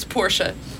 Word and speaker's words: Porsche: A-Rod, A-Rod, gave her a $140,000 Porsche for her birthday. – Oh, it's Porsche. There are no silent letters in German.